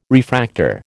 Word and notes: refractor: American English

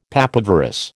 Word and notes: papaverous: American English

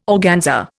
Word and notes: organza: Indian

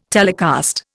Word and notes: telecast: Indian

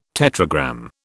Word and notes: tetragram: British English

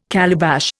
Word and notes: calabash: Indian

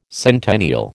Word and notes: centennial: American English

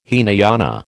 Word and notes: Hinayana: American English

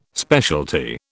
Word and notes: specialty: British English

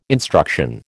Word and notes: instruction: American English

